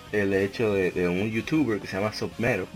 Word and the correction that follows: Submato: Mato se escribe, Submato,